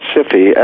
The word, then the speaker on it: SIFI: G-Sci-Fi, or I think they pronounce it SIFI, S-I-F-I, but you're right, G-Sci-Fi.